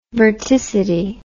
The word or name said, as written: verticity